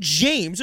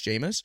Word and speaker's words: Jameis: I'm like, "How do you pronounce it then?" And they're like, "It's James." And I'm like, no, it's not James. There's no way that can be pronounced James. And I'm like, I've heard it pronounced like Jameis. I swear. And then, like, we never had the conversation again about it, but it's obviously it's Jameis.